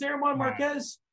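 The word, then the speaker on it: Germán Márquez: Herman. It's Herman Marquez. – Herman. I've never heard anybody pronounce Herman. I think you're just making things up at this point. – Why would I make this up? It's Herman Marquez. We'll talk about him soon enough.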